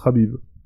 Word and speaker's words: Khabib: Habib ou Khabib, mais bon, ça se prononce Habib.